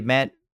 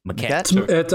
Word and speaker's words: Maquette: I forgot how to pronounce it already. Maquette? – Maquette. – I believe it's McQueedy.